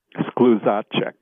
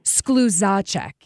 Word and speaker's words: Skluzacek: Just put the accent on the second syllable and you will be 100%. – All right, you know, I have to ask, can you say it for me? – Skluzacek.